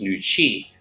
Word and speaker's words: Nooci: Nooci in Chinese is pronounced Nooci, which means women's energy.